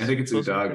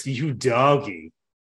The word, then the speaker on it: Udogie: Udogie, I think is how it's Udogie. Pronounced. Yeah. And then, I mean, when he makes a sick move, it's Udogie.